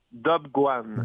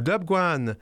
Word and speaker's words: Dobguan: J'ai bien prononcé Top Can, c'est ça? – Pas tout à fait, mais je te pardonne, écoute, c'est normal. On prononce ça plutôt Dobguan. – Dobguan.